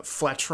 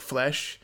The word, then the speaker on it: Fletch: Like Marcia Gay Harden has a bit where she's like an Italian and she pronounces Fletch wrong. She says like flesh. She's like flesh.